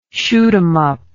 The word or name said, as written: shoot 'em up